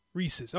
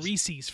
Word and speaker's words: Reese's: Do you say Reese's or Reese's? – Reese's. – Reese's. Okay, and I think that is the correct pronunciation. – Yeah. – I was saying Reese's for years.